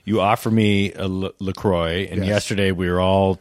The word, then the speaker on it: LaCroix: It rhymes with boy, LaCroix.